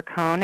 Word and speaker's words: Cohn: I think you just search for my name, Jennifer Cohn. It's spelled okay. – C-O-H-N.